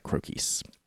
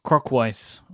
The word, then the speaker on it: Croquis: I think it's pronounced like Marquise, right? Like Crokies. – Crokies. – Mm-hmm. – Not Crokweis. – Not Crokweis.